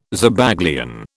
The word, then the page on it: Zabaglione: British English